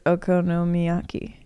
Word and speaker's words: okonomiyaki: Best thing I ate in Tokyo, it was okonomiyaki. – That's how you say it. That's correct.